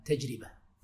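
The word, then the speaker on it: تجربة: طبعا النطق الصحيح لها تجربه. يقول لك التجربه من الجرب ولك من الشر.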